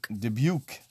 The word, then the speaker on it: Dubuque: Dubuque.